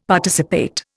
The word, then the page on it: Participate: Indian